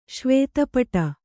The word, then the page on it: ಶ್ವೇತಪಟ: Kannada